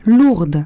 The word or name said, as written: lourdes